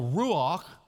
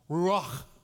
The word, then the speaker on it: ruach: Well, ruach means breath in the Hebrew, and that's because if you say it right, you can actually hear the breath. Ruach.